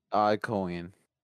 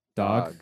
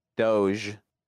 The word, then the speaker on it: Dogecoin: You say it dog. – Do you? – No. – You're being a dick again. He always does that to me. – How do you pronounce it, man? – Doge. Doge. Rouge the Bat, but Doge. Okay.